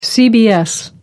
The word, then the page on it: cbs: American English